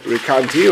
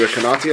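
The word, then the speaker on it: Recanati: On a little bit more of a mystical level, you have the Rikanti, or the Rikanati, I think it's supposed to be pronounced,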